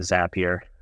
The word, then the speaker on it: Zapier: And, you know, we're making people happier with Zapier. That's why the name, it rhymes with happier.